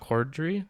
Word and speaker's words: Corddry: And then Rob Corddry, that's how you say his name, right? – Yep.